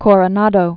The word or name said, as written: Coronado